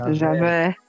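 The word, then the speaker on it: Javert: Então ele canta isso e essa parte aqui é fantástica porque descreve muito bem um pouco, né, da personalidade do Jaffer. É isso? Falei certo? – Javé. Javé. Javé. – Javé. De vaca.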